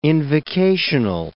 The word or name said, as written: invocational